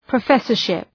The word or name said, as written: professorship